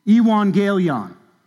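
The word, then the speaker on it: Evangelion (εὐαγγέλιον): The original word in Greek is pronounced this way, Evangelion.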